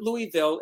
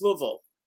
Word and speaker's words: Louisville: Carol Channing, who was a friend of mine, always said it wasn't Louisville, it's Louisville.